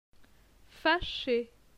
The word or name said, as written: Fache